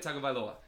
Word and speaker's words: Tagovailoa: Talia Tagovailoa.